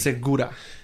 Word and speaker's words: Segura: Okay, you pronounced my last name correctly, like segment two, but then segment one, you messed up, and now you're back at it again. It's okay. – Gabe. – Segura.